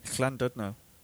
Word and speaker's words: Llandudno: So can you pronounce Llandudno better than me? – Llandudno. Probably. – Yeah, that's right. Yeah, that sounds good. Yeah, it's pronounced with a C, isn't it? But Llandudno, I call it.